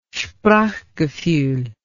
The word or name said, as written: sprachgefuhl